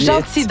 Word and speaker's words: Jeanty: Ah, oh, Gentil, donc on dit, ça s'écrit J-E-A-N-T-Y.